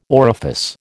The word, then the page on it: orifice: American English